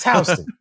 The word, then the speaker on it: Houston: Don't ever hit me with where's Houston Street. – Right? It's Houston. So it's one of those things where when you live a certain place, sometimes you pronounce some shit kind of fucked up.